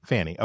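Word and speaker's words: Fani: But they convinced, how do we pronounce her first name? Is it Fanny or? – Fanny. – Fanny. Okay. I've heard it pronounced different ways. It looks like a Fanny, or looks like it should be pronounced Fanny, rather, I should say.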